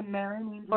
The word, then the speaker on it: Marinangel: Cindy, am I pronouncing your name right? Is it Marinangel? – Yeah, Marinangel. – Or Marinangel. Okay.